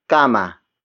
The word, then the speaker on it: kama: It is the focus marker, indicating that the next word is the focus of this sentence, and that is kama. Kama. Kama. Kama is spelled as K-A-M-A.